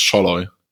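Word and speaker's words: Szalay: Also ein SZ wird zu einem scharfen S im Ungarischen. Mhm. Und ein S spricht man wie ein SCH. Und durch das Doppel-L werden diese A's scheinbar zu O's. Also man spricht es Scholloy.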